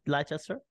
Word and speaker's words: Leicester: Okay, guys, get rid of the I. Leicester. Fine. Let's say Leicester.